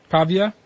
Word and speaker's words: Pavia: Pavia? – Pavia.